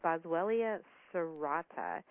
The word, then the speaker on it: Boswellia serrata: Absolutely, and that is, I hope I'm going to pronounce it right, Boswellia serrata. Is that correct? – Perfect.